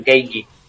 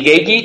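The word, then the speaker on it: Gaethje: Eh, vale, hay que poner la boca como acento canario, Geiji.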